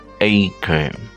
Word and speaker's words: Anchor: En inglés eh, se pronuncia Anchor.